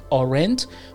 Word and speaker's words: aren't: نباید بگیم آرنت. در واقع تلفظ درستش این هست: آرنت، آرنت، آرنت غلطه. پس اگر تلفظ میکنید aren't، باید انقدر تمرین کنید که این رو بتونید تلفظ کنید aren't.